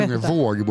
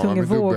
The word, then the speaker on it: Tungevåg: Mm, DJn heter Tungevag. Är det ja. som man uttalar det? – Jag Tungevåg borde det vara, Tungevåg. med dubbel A.